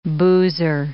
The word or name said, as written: boozer